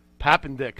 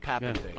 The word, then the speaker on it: Papendick: How do you say this correctly? Hold on. – Papendick. – Papendick. – Yeah. Okay. – All right. For once, it looks like it sounds, you know.